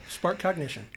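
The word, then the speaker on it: SparkCognition: Spark Cognition.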